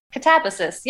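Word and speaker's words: Katabasis: And so there's a clip from the interview with Wells Sister about kind of the background of the record and the name, the Catabasis name, which I think I'm pronouncing right. I know I screwed it up a couple times on the actual interview, but check that out, followed by a song. – Catabasis, yeah. It's a Greek word from mythology, which you clued into, yeah.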